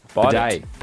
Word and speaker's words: bidet: B-I-D-E-A, remember? – Oh, I don't know. – What is bidet? Like ballet.